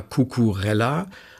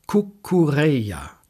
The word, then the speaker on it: Cucurella: Der Mann heißt aber weder Cucurella noch Zucurella und auch nicht Cucurella, sondern sehr elegant Cucurella.